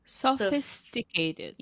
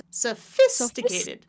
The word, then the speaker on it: sophisticated: This one has five syllables. So I'm going to do the syllable stress on the second one. So it sounds like this. Da da da da da da. Da, da, da, da, Sophisticated. yes, there you go.